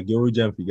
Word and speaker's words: Gilbert Gyamfi: Yeah, Gilbert Gyamfi.